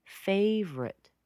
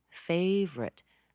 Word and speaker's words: favorite: That also has one syllable that's missing. Can you hear what that is? Favorite. Favorite. That O's missing there.